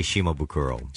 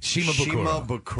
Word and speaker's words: Shimabukuro: How do you say it, Jake? – Well, I say Shimabukuro. – Shimabukuro.